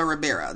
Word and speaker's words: Ribeiro: Recently, Alfonso, how do you say Carlton's last name? Is it Ribeiro? Ribeiro? Ribeiro. Alfonso Ribeiro. That's it. That sounds right.